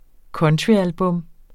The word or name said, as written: countryalbum